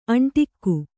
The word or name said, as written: ಅಂಟಿಕ್ಕು